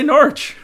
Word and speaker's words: Norwich: That's how you say Norwich. – It's Norwich.